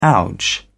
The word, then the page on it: ouch: British English